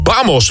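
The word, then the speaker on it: Vamos: Lange Frans was dat samen met Tommy Costa en Bamos. Volgens mij spreek je het zo uit. Je spreekt het, of je zegt het met een B, maar je schrijft het met een V.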